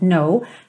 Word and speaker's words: know: Então aqui, percebe que o no, esse K é mudo, então você está vendo ele escrito ali, mas na hora de pronunciar ele não soa, então you don't know how lovely, você também não diz lovely, tá bom?